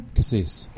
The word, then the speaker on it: Kassees: Kassees. – Kassees. – Yeah, like as in creme de.